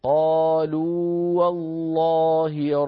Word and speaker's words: قالوا والله: أيضا عندنا في الآية 23: ثم لم تكن فتنتهم إلا أن قالوا والله، قالوا والله ربنا، قالوا آخرها واو مدية، والله أولها واو متحركة، التقى في النطق واوان، ولكن الأول من المثلين وهو الواو الأولى واو مدية، فلا إدغام بين الواوين لأن حرف المد لا يدغم في الذي بعده، كما